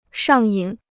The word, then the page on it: 上瘾: shàng yǐn